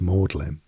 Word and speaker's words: Magdalene: or Magdalene is pronounced Maudlin, and Worcester is pronounced Worcester.